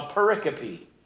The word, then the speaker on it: pericope: Each one of those little stories is called a pericope. Do you need me to spell that for you? – Yeah. – Okay. P-E-R-I-C-O-P-E.